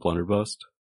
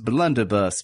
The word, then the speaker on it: blunderbuss: Hey, mind if I look at that blunderbust? – I believe that it's pronounced blunderbuss, but you can absolutely look at it if you'd want to.